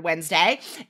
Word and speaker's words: Wednesday: And then also the word Wednesday, because again, same thing, right? We don't say it like it's written.